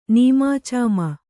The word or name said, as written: ನೀಮಾಚಾಮ